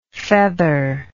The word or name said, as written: featherlike